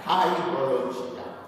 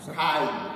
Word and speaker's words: kairologia: Si scrive perché viene dal greco, no? Con la K. Come si pronuncia? Kai, K-A-I-R, ok? Logia. Bene.